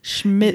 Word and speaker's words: Schmidt's: It's Schmitz. – Schmitz. – You know my issue with pronouncing things. – Schmidt. Yes. Now I can't say it. Thanks. SCHMIDT'S. There you go.